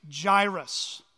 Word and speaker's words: Jairus: His name is Jairus. It rhymes with virus.